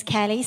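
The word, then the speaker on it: Cally: Just as a little brief introduction, my name is Callie, C-A-L-L-Y. I spell it because I get called Kelly a lot, but I answer to that.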